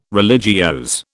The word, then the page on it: religiose: British English